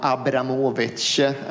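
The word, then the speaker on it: Abramovich: Ifall jag får drömma helt fritt och ifall jag får önska mig saker utan begränsningar så skulle jag vilja ha hit Roman Abramovic. Man uttalar tydligen namnet så ifall man ska vara en korrekt ryss, och det vill vi ju alla vara.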